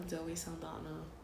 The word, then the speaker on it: Zoe Saldana: Zoe Saldana? Some of the. – Zoe Saldana.